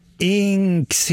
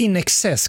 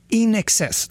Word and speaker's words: INXS: Need You Tonight med Inks heter de ju inte. De heter ju In Excess. Camilla, vad tänker du om de här namnen? INXS. Så heter ju de, men man uttalar det In Excess.